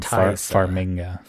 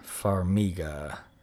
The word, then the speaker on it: Farmiga: I always pronounce their last name Farmiga. – Farmiga. – Isn't there an N? – Nope.